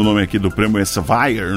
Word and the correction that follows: Elsevier: né? Então, é Elsevier, né?